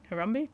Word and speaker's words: Harambe: this gorilla called Harambe. Am I saying that right? Harambe? – You are saying that correct, yes.